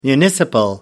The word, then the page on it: municipal: American English